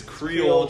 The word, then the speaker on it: Creole: That Caroli. – Okay, first off, this guy doesn't know how to say it. It's Creole chicken.